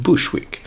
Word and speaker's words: Bogsvik: Det är något som heter Bogsvik, fast det uttalas som Bushwick. Uttalas som Bushwick, som ligger i Brooklyn i New York.